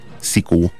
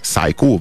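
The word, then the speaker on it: Sicko: helyezett nem más, mint a Szikó, vagy Szájkó, vagy hogy kell ezt kiejteni? – Szerintem Szikó, mint Szik, az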